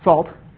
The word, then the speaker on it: NaCl: That's, of course, pronounced salt, and the C version of the library is pronounced C, salt, C, get it, C, salt, ha, ha, ha.